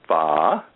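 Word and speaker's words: phở: I call it pho. Some say pho. And I love it, by the way. I've been told by a Vietnamese friend of mine that the proper pronunciation is pho with an ascending A.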